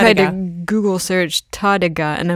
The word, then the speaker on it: Tárrega: As if there's an Tárrega, as if there's an accent on the E, but there's no accent, it's on the A, so it's Tárrega.